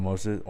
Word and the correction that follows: Moises: or Moises. So then Thiago Moises just pretty much got the Khabib treatment, which is now becoming the Islam treatment.